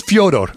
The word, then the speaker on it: Fyodor: We asked Fedor one time, what does your mother call you? He says, Fyodor. I said, we call you Fyodor.